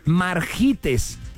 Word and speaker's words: Margites: Margites. M-A-R-G-I-T-E-S.